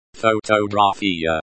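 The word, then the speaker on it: фотография: It is pronounced photographia. The stress is on the third syllable. Gra. Photographia.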